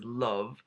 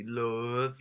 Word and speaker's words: Loev: which is Love, which is spelled L-O-E-V, Love.